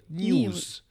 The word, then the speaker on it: NIUS: Das heißt N-I-U-S, also News. – News.